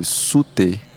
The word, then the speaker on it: Soothe: Acho que é tipo, escreve. Vou, vou falar como se escreve. Sute. Escreve. – Sute. É Sute? É. – S-O-O-T-H-E.